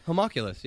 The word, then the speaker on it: homunculus: Am I pronouncing it correctly? – Yeah, homunculus. Yeah, that's Do you even say the N? – I don't. I've never heard anyone pronounce it that way. It must be like a silent letter or something like that.